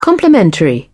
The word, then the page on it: complimentary: British English